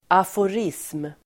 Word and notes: aforism: [afor'is:m]

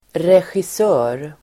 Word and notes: regissör: [resjis'ö:r]

